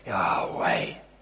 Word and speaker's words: Yahweh: It's not actually spoken aloud, but it's actually breathed. And the pronunciation is when you breathe in, Yahweh, something like that. It's in inhaling and exhaling.